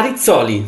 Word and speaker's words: Rizzoli: Rizzoli? – The publishing house? – Ah, Rizzoli, sorry.